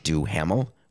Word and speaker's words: Duhamel: Did I say his name wrong? It's just Josh Duhamel. What did I call him? Josh DeShamel? I don't know what I called him.